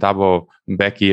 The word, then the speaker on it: Thabo Mbeki: So your piece that's pinned on your Substack is called You Were Right, President Thabo Mbeki, if I pronounced that right, yep.